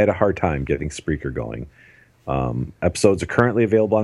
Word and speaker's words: Spreaker: In the event that you are listening to us on, how do you say it, Rob? – Spreaker? – Spreaker.